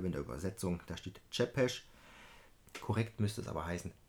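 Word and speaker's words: Țepeș: Denken Sie bitte daran, es wird Chepesh ausgesprochen. Im Original steht es äh, auch so ähnlich drin. Remember, it is pronounced Zepesh.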